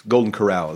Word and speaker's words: Golden Corral: Hey, is Golden Corral, it's Golden Corral, is that how you say it? – Yeah, hard C.